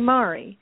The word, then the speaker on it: Mahri: Well, I was born Mahri Gresham Ingalls, and Mahri was spelled like M-A-R-I-E, and in Scotland, there was two different ways to pronounce that. You could pronounce it as Mahri or Mahri, and my parents wanted it to be Mahri. And